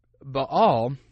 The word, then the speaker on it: Baal: And what a lot of people miss in this story, by the way, this little side note, is that that Baal, if you want to pronounce it properly, Baal was supposedly the lord of the sky.